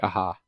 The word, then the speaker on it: Aja: I think we were saying his name wrong. I saw someone else pronounce it this week. I think it's David Aja. – Oh, because the Spanish J? – Something like that, yeah.